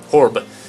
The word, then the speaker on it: Horeb: Is that how you pronounce Horeb, that? Yeah. – Horeb? Horeb.